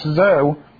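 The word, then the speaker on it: zoe (ζωή): Now, the word life that's used here is a Greek word pronounced zoe.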